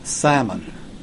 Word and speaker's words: salmon: You say salmon, too, probably. – Salmon. – Oh, salmon. Okay, all right. – The L is silent.